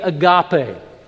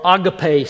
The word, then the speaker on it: agape: The word for love that is most used in the New Testament is, the English translation of it would be agape, or agape, some people pronounce it, since I don't know any people that speak Greek as their first language. I'm not sure how, but I know how to spell it, A-G-A-P-E.